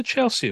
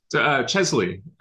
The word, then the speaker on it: Chesley: What's a Chelsea award? – Chesley.